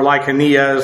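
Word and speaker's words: Lycaonia: Lyconia, or Lycania, as some of them pronounce it, Pamphylia, Phrygia, Lycia, and then Asia is this bigger area right in here.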